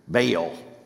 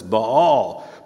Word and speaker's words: Baal: And I think, like you, I grew up where it was given a particularly strange Southern pronunciation, Baal. It's like, if it's two A's, you just hunker down on the A. Baal. It's a diphthong, so it's Baal.